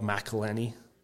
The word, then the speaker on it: McElhenney: And Rob McElhenney. – Say his name again. – I have an issue with this. It's Rob McElhenney.